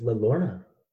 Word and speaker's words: La Llorona: So the first issue of Batwoman opens up with La Llorona, probably very not pronouncing that with any sort of a Latin accuracy at all. – I thought it was La Llorona.